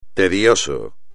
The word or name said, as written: tedioso